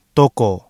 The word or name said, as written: toko